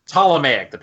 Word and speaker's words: Ptolemaic: I want to call Ptolemaic. Ptolemaic, the P is silent. Thank you.